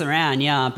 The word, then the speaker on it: Aran: It's A-R-A-N. – Aran. – Aran, interesting. What about you guys? – Aran. – Aran. – I say Samus Aran, but...